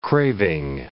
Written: craving

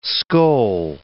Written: skoal

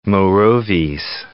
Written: Morovis

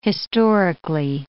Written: historically

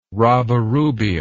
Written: rabirubia

